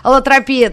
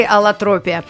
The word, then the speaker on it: аллотропия: Говорим о интересном явлении аллотропии, но нас примирила не только Википедия проклятая, но и вообще словарь, который допускает ударение как на слог с буквой «и» аллотропия, так и аллотропия.